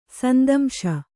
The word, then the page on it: ಸಂದಂಶ: sandamśa